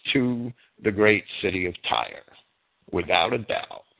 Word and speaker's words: Tyre: they rhymed the word so that you would pronounce it as Tyre, T-Y-R-E. That's Kipling rhymes it with fire, so he has to be pronouncing it as Tyre.